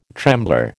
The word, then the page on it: trembler: American English